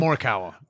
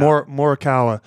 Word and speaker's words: Morikawa: Malakari. What's it? How do you say his name? – Morikawa. – Yeah. Morikawa.